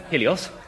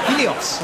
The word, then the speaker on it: Helios: I'm Helios. – Helios. Oh, am I pronouncing that right? – Yes, that's it.